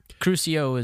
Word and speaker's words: Crucio: Crucia, I'm going to mispronounce that every time. – Crucio is the actual, like, how you cast it.